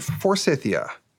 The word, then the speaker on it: Forsythia: Is it Forsythia? – Yes, you're right. Okay, it's just like the flower.